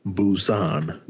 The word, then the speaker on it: Busan: I believe that is pronounced Busan.